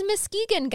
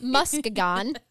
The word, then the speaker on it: Muskegon: When we cover a Michigan case, I'm like, oh, yeah, it's Muskegon, guys, not Muskegon.